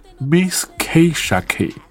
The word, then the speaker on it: Misskayshak: 然后他这个发音应该是叫做 Miss K Shack，这个对，然后名字也挺奇怪的，对，但因为他拆开念就是 Miss K Shack，对。